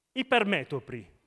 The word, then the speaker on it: ipermetropi: Oltre l'essere miopi, è importante, è fondamentale che noi non siamo ipermetripi. Ho detto giusto stavolta, no? Ipermetropo. Vabbè, il plurale. Ipermetropi.